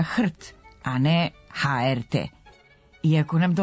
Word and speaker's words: HRT: S druge strane, skraćenicu za Hrvatsku televiziju ne izgovaramo slovo po slovo, nego kao jednu reč, HRT. Taj nam je izgovor blizak jer je to homonim za reč kojom se označava pasmina pasa i koja odavno postoji u našem jeziku, ima slogotvorno R, što olakšava izgovor i srodna je sa rečima krt i vrt.